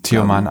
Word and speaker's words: Tioman: Tioman.